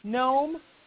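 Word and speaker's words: Noem: Nome in South Dakota. Is that how you say her name? K-O-E-M.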